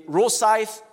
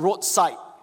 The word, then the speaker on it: Rosyth: I studied in Rosyth Primary School, not Roadside Primary School. That's how people pronounce it, but Rosyth Primary School.